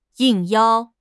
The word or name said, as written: 应邀